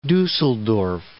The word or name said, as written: Dusseldorf